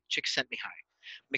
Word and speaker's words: Csikszentmihalyi: And this in some ways taps into what I think his last name is pronounced something like Csikszentmihalyi, Mihály Csikszentmihalyi. And he investigated flow.